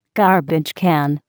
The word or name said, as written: garbage can